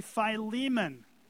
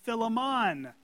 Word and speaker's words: Philemon: Everybody say Philemon. All right, and if by chance you grew up with an alternate pronunciation, everybody say Philemon.